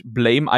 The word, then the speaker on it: Blame: Es wird Blam ausgesprochen. – Ach, ist, verdammt. Ach, es ist, äh, ja. ich, ich weiß, es wird halt Blame eigentlich geschrieben, ja. aber es wird tatsächlich Blam ausgesprochen.